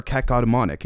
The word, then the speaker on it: cacodemonic: So, first off, the title Camp X Geppetto, garnering cacodemonic, or cacodemonic, excuse me, it's a little bit hard to, to pronounce, annealments to manifest alternative arcanes, and in brackets, universe number 25 and Phobos 2.